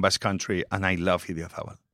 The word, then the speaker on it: Idiazabal: How do you pronounce that? – Idiazabal. Very good.